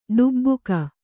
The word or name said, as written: ಢಬಕು